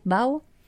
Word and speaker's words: bao: Okay, so they're juicy braised pork belly topped with yakiniku sauce and Japanese mayo in a soft bao. Is that how you say it? Bao? – Bao.